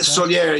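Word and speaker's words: Sóller: Is it Soler? Is that how I pronounce it? S-O-L-L-E or Soler? Something like that? – Soler, yeah. So when we're not actually in Soler anymore.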